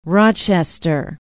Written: Rochester

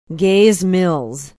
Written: gays mills

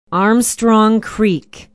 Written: armstrong creek

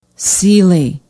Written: Seeley

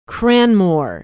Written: Cranmoor

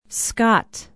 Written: Scott

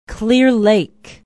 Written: Clear Lake

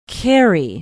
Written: cary

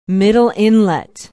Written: middle inlet

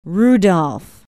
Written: Rudolph

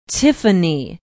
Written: tiffany

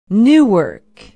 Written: newark

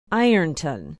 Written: ironton

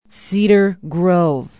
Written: cedar grove

